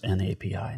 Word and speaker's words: N-API: I just remembered I think it's pronounced N-A-P-I, not nappy.